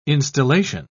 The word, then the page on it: instillation: British English